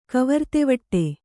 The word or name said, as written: ಕವರ್ತೆವಡೆ